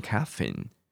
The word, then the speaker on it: caffeine: 你念 caffeine，caffeine，重音你摆前面或摆后面都可以哦。这个字你可以念 caffeine。 caffeine 或是 caffeine 都可以，OK，好，或是很激烈的这种活动，好，你有一些你 do some exercise，好，sports，你有这种运动，比较容易会这样子。